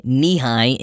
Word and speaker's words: Nehi: Well, being spelled in the non-obvious way, Nehi, N-E-H-I, the company, had to do a little subliminal messaging and brand awareness to instruct people on the proper Nehi pronunciation, thus featuring a logo of a leg ending at knee height.